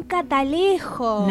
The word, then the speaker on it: catalejo: Un catalejo. – No. – Te lo juro. – ¿Cómo se dice? – Catalejo, como cata de Catalina y lejo casi de lejos, pero no.